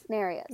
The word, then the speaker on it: Nereus: And then we end up, I don't know how you say his name. You guys listen to the audiobook, right? Is it Nereus? Nereus? The sea god. – Nereus.